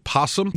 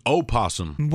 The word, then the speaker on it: opossum: It's an opossum. – What do you mean? – It's not a possum. It's an opossum. There's an O. – At the beginning of the word.